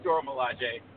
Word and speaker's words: Dora Milaje: No, it is Dora Milaje. I forget about the little accent on the E. – Gotta. – I gotta put. – If it was a romance language, it probably would be Milaje. But it's an African dialect and stuff. You gotta hit that J.